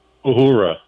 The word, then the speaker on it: Uhura: Uhura. – Uhura.